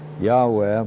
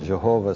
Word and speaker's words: Yahweh: Now, some Hebrew scholars are telling us that it ought to be pronounced Yahweh, but we've pronounced it Jehovah for so long, I think it really confuses things to change. When you have a capital L and a small O-R-D, the word is Adonai.